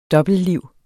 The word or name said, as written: dobbeltliv